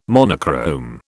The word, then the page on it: monochrome: British English